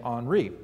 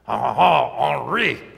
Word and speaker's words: Henri: And did you know that Henry, the English name Henry, is actually a play off the French name Henri. Oh, sorry, I didn't say that right. Henri. There we go.